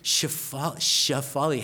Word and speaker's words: Shefali: because her name was Shefali. And so when they asked her, is there a name for this order? And she would say, Shefali, and they would say, what? And she would say, Shefali, how do you pronounce that? Shefali, how do you spell it? S-H-E-F-A-L-I.